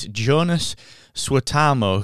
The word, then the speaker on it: Joonas Suotamo: Uh, is it Jonas Swatamo? Jonas. According to Wikipedia, it's Jonas Suotamo, who is the Finnish actor and pro basketball player who took over from Peter Mayhew as Chewbacca.